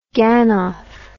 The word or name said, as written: ganof